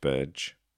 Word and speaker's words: Burge: And Gary Rogers 49 went a bit further and said, the apotheosis of Saint Burge, apologies if I mispronounced that, but yeah, made both goals, man of the match. – No, it's Burge, you said it right.